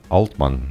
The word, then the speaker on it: Altman: Altman, ναι. Altman είναι η προφορά τους, υπάρχει μια δισκοβόλος με αυτό το όνομα.